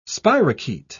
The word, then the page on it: spirochaete: British English